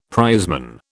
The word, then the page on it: prizeman: British English